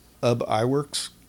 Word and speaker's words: Ub Iwerks: But there was a guy at Disney, Ub Iwerks.